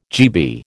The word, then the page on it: GB: American English